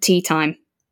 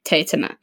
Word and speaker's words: Teatime: Because so it's a major part of the book that his name is spelled T-E-A-T-I-M-E. – Yeah. – And that people say tea time, and he gets really annoyed by that because he pronounces it Teotima.